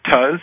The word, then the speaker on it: Tuz: Our guest tonight has been Craig Tuz. Is that the correct pronunciation? – That's correct. – T-U-Z, Craig Tuz. You should check out his film, Gravity Hill of Sylmar, and his book, Pieces of the Puzzle.